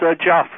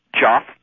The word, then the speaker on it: Jeff: Hello, this is Joff from Newark. – Who? – Joff. – Joff. – As in Jeff? – As in Jeff. That's how it's spelled, yes.